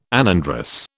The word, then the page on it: anandrous: British English